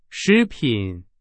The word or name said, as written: 食品